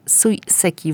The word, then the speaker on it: Sui Seki: Kuratorem wystawy Sui Seki w Muzeum Sztuki i Techniki Japońskiej Manga w Krakowie. Dobrze to wymawiam? – Tak.